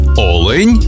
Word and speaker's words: олень: І в однині, і в множині наголос падає на перший склад – олень та олені.